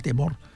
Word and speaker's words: temor: Perquè aquest R final, a l'Ebre, ja també la pronuncien, la temor.